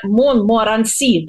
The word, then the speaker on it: Монморанси: Вот, и экранный Монморанси, да, ударение последний слог, предупреждает, что вообще вся страна может вспыхнуть.